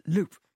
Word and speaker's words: loups: There are these villainous characters in the book, spelt L-O-U-P-S. Yeah. Are you pronouncing them loup, as in the French, or English loup? – I think David will pronounce it loup. – Loup.